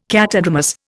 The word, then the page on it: catadromous: Indian